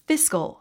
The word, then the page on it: fiscal: físk ə l; フィスコォ